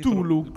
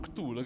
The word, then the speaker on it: Cthulhu: Perfetto, come potete vedere in mezzo ho messo il eh, Bond, eh, come si Cthulhu. – Cthulhu grazie della, di avermi aiutato nella pronuncia.